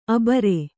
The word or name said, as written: ಆಭೀರಿ